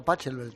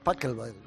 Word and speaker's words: Pachelbel: No, es que yo decía Pachelbel también. – Sí, pues. – Y luego me dijeron, no, es Pachelbel.